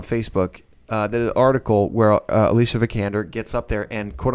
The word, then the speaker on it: Vikander: Alicia Vikander. All right. – Vikander. Say it right. – Vikander. – There you go.